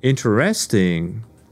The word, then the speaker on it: interesting: The word interesting has the stressed part on the first syllable, which is interesting. Interesting, not interesting. Interesting. Interesante in Spanish, but in English it's interesting.